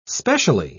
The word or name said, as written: specially